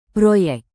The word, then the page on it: proyek: Indonesian